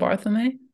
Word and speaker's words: Barthelme: Donald Barthelme.